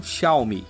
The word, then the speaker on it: Xiaomi: Hugo Barra, vice-presidente da companhia oriental, explica que Xiao significa pequeno e Mi quer dizer arroz. A pronúncia correta do nome dessa empresa, que de pequena não tem nada e já está com tudo armado para chegar aqui no Brasil, é então Xiaomi.